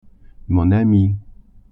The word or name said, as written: amie